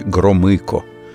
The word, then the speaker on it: Громыко: Министр иностранных дел Андрей Андреевич Громыко – Именно так подчеркнуто внятно произносят фамилию члена Политбюро советские дикторы.